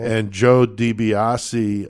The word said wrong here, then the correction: DiBiase: or DeBias, excuse me, I've pronounced it wrong all these years.